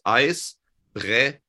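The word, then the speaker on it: Eisbrecher: It's actually not, if you want to pronounce it correctly, it's Icebreaker. It's the sh. – Okay. – The ch can be two things. It can be a ch and it can be a sh.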